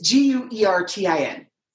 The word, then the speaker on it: Guertin: Do you know how to pronounce that one? – Wharton. – Wharton? – Wharton. – Wharton. Okay. Mike Wharton. I'm putting an Irish inflection on that. I don't know why. It's Guertin. Do you guys have a guess? – I would go with Wharton.